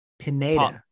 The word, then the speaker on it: Pineda: Paula Padina. – Pineda. Looks like, yes, Pineda. Yes, thank you. I knew I was saying it wrong. Pineda, you got it.